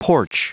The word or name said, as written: porch